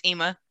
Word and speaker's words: Ima: It's Ima.